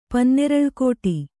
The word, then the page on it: ಪನ್ನೆರಳ್ಕೋಟಿ: Kannada